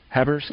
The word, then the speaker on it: Haberski: Am I pronouncing his name right? Habersky? – Yes, Habersky.